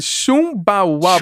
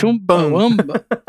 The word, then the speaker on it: Chumbawamba: E é chu, é, escreve Chumbawamba. Chumbawamba. – É, Wamba né? Chu, é, e aí... Chumbawamba.